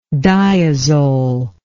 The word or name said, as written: diazole